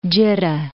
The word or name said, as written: Jiddah